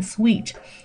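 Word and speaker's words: suite: 长得虽然很像，后面加个 e，但是呢，事实上那个套房的发音是念 sweet。跟这个糖跟甜蜜的这个词 sweet，s w double e t 这个词它是一样的发音，不要搞错了，OK？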